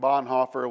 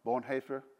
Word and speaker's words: Bonhoeffer: Dietrich Bonhoeffer was, I probably said that wrong, Bonhoeffer? Okay, there we go.